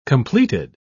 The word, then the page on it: completed: British English